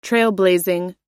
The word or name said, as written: trailblazing